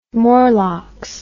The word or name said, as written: Morlocks